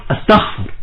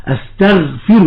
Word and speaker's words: استغفر: ولذلك عندما يعني تجد بعض الناس ينطق كلمة استغفر، عندما يتسرع يقول استغفر. الذي حدث هنا أن الغين وهي من حروف الجهر انقلبت إلى حرف مهموس هو الخاء، فقال استغفر، والصواب استغفر الله العظيم.